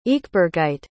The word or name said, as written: ekebergite